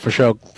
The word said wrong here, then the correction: Fauché: It's Fauché is the pronunciation.